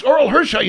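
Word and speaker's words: Orel Hershiser: It's Oral Hershiser.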